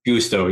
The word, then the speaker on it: Gusto: Gusto, yeah.